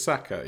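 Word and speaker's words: Sacca: How do you say it? Chris Sacker, Sasha. – Chris Sacker. Yeah.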